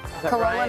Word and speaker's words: Corolla: Did I say that right? – Corolla, North Carolina. – We'll buy it. Yes. – That's right.